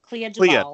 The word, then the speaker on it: Clea DuVall: Is that how you say it? – Clea DuVall. – I think it's Clea.